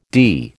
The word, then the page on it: dee: American English